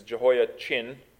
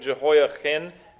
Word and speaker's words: Jehoiachin: Jeconiah's other name is Jehoiachin. And I pronounce that CH pretty purposefully because in the Hebrew, it's a lot of fun, the differences between Jehoiachin and Jehoiakim.